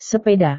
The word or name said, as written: sepeda